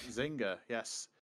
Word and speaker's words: Zynga: Burridge, can you pronounce that for me? Is it Zynga? – Zynga, I think. – Zynga, yes.